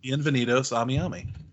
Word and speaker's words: Bienvenidos a Miami: And un benito a Miami. Sorry, Will Smith's song is in my head now. I don't even think I pronounced it right. – Bienvenidos a Miami.